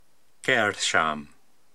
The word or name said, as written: Keyrsham